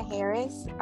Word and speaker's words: Harris: So my name, Melinda Harris, on Facebook, and that's spelled M-E-L-I-N-D-A, last name Harris, H-A-R-R-I-S.